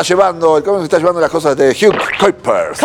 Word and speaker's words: Kuipers: ¿Cómo es? ¿Cómo se pronuncia? – Kuipers. – Pueden decir Kupi, ustedes. – Kupiers. – Kuipers. – Kuipers. – Hugo Kuipers. – Kuipers. Kuipers. Ok. – No, es Hugo Kuipers.